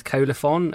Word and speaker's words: Colophon: But today I'm in with Colophon. Is that how you pronounce it, by the way? – Yeah. – It is, right.